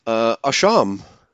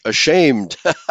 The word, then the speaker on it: asham: I'm looking at the word guilty, and that's Hebrew 8:16, asham, so which sounds like very much like the English ashamed, okay, and are ashamed. So that's probably where we get our English word ashamed from. It's spelled A-S-H-A-M, or alternatively A-S-H-E-M, but they pronounce it asham.